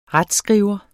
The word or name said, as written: retsskriver